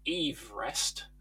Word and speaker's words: Everest: And pausing just for a second to say that Sir George pronounced his surname Everest, so we're all pronouncing it wrong.